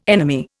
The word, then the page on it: enemy: Indian